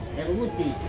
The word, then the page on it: rut: Ansus